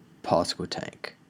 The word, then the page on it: Pasquotank County: American English